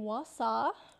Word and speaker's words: Wausau: So the family lived in Wausau, Wisconsin. – Yeah. – You've been to America. Am I saying that right? – Wausau. Yeah.